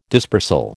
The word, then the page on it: dispersal: American English